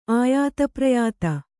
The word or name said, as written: ಆಯಾತಪ್ರಯಾತ